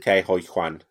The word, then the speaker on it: Ke Huy Quan: And how do you say his name? Ki Kwai Kwan? – Ke Huy Quan.